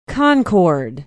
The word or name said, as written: Concord